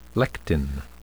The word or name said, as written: lectin